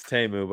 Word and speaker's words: Temu: It's Temu, by the way.